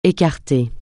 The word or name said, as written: écarter